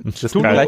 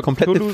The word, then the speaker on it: Cthulhu: Und es gibt keine richtige oder falsche Aussprache von Cthulhu. Weil das kein menschlicher Laut ist nach seiner eigenen Definition. Du kannst es nicht verkehrt aussprechen, weil du es als Mensch eh nicht aussprechen kannst.